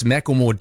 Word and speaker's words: Macklemore: It's my understanding that there's a slight L in the middle, but more of a diphthong than really an extra syllable. – Oh, so it's Macklemore diphthong.